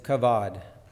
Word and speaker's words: kavod: And the Hebrew word, oh, here it is kavod, if I'm saying that right, K-A-V-O-D, which comes from the word for weight, and particularly and typically refers to something heavy.